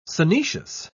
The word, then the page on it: synoecious: British English